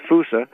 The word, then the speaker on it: fossa: It's spelled like fossa, like F-O-S-S-A, but pronounced fusa.